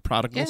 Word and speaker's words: Prodigal: Bo99 came in and said, according to Sonny, this song was inspired by the story of, I'm going to say this word wrong because I always say it wrong, Prodigal Son. Did I say that right? – Yeah, the Prodigal Son.